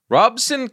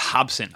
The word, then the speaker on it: Robson: Co-main event, Raymond Morataya is fighting Robson Cansecao. How is Robson Cansecao getting these chances? – I believe it's pronounced Hobson. I think their R's are H's over the Brazilian.